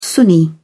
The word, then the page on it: sunni: British English